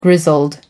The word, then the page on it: grizzled: American English